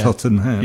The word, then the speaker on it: Tottenham: He wanted four syllables, Tottenham.